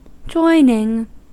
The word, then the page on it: joining: American English